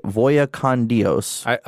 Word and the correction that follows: Vaya con Dios: How would you say it? – Vaya con Dios.